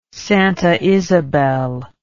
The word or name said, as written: Santa Isabel